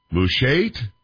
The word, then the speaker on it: Mushait: I don't know how to say this, M-U-S-H-A-I-T, Mushait? Can we say, we're going to go with that? Paul, are we good with that? You're not top of that, are we? – Say it the way you need to say it. – Mushait? – Yes. – That's good.